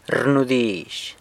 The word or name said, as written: rnudizh